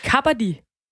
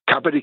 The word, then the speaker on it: kabaddi: Så det er faktisk, jeg har også udtalt det helt forkert, det er kappadi? – Ja, faktisk, når, når, når, de, når, når, de, når de løber, siger, så, så siger de kappadi, kappadi, kappadi.